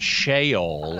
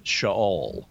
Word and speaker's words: Sheol: It is not Sheol. It's Sheol. Good. – Well, thank you. I learned. If I lie down in Sheol, you are there too.